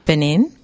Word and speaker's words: Benin: I feel like I just went on a journey to Benin. Did I pronounce that right? – You did pronounce that right.